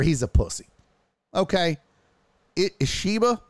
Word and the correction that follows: Ishbia: was it? How do you pronounce his name? Ishiba. Yeah, was it Matt Ishiba? Did I get that right? Yeah, Ishbia. Yeah, I always fuck it up.